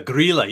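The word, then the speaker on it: Grýla: The Grilla. – The Grilla.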